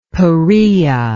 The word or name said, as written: Peraea